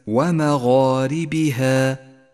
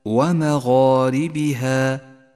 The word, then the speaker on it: ومغاربها: فتأمل، فخم الألف عندما تقرأ كلمة ومغاربها، ومغاربها، واعلم أنها فخمت لأنها سبقت بحرف مفخم، فالألف تتبع ما قبلها في الترقيق والتفخيم. بينما لاحظ الألف الثانية من نفس الكلمة كيف جاءت مرققة، لأنها سبقت بحرف مرقق هو الهاء.